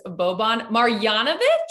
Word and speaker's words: Boban Marjanović: For the Dallas Mavericks, Boban Marjanović? Did I say that right? – Amazing. I was like, wow.